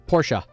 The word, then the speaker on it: Porsche: Is it Porsche or Porsche? – It's actually Porsche. – I thought it was Porsche. – Yeah, you pronounce the E.